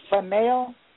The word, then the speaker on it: spinel: And you said black spinel? – Spinel. S-P-I-N-E-L.